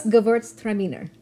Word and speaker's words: Gewürztraminer: It's Gewürztraminer.